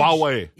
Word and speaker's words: Huawei: That great big huge Huawei.